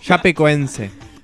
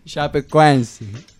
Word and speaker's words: Chapecoense: Chapacé. – Chapecoense. – Chapecoense.